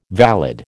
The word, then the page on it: valid: American English